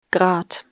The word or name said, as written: Grad